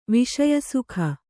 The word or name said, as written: ವಿಷಯಸುಖ